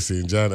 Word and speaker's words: St. John: And he was talking about the weather over there in St. John, and he actually spelled it like how we pronounce it. S-T period J-A-N, St. John. So I forgot to mention that yesterday, you know. Yeah, that. When he write it, you know, he used the local enunciation, you know, so that was cool, St. John.